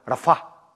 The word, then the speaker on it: Рафа: Ну, я слушал, как евреи это произносят, чтобы правильно произносить, то они говорят Рафа, Рафа, Рафа, что значит Бог целитель, и Егова целитель.